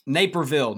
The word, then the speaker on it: Naperville: Did I say that right? Naperville? – It's close. Naperville. – Naperville, yeah. Near Chicago.